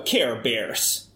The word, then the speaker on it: bear: För att det var ett ex till mig när jag sa care bears. Då sa han, men nu uttalar du det rätt. Care, bear. Och sen beer. Det är väl lite mer i.